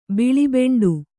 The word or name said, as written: ಬಿಳಿಬೆಂಡು